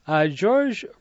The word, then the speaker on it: Georges: Georges Renault, his name George, not it's not pronounced French, but Georges Renault, the blind musician of the Smoky Mountains.